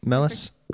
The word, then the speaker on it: Mellas: Is that is that correct, Mellas? Yeah. Mellas. Okay. Except when we're in California, it's Mias. – Mias. – Spanish pronunciation. Ah.